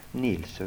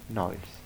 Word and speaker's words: Nils: Nils uttalas det. – Nils. – Hur? – Nils. Mig. – Ja. Jaha. Det är aldrig diftong på det. Niles.